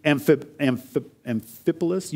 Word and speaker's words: Amphipolis: Now, when they had passed through Amphipolis, you pronounced it correctly, I think, Kyle, thank you, and Apollonia, they came to Thessalonica, where there was a synagogue of the Jews.